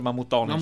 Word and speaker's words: Mammutones: La parola sarda si pronuncia Mammutones. – Mammutones. – Mammutones, così con Mammutones. Io l'ho fatta un po' più americana, dai.